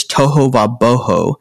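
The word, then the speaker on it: tohu va-bohu: So I'm going to spell it T-O-H-U, toho va-w-a, boho, B-O-H-U.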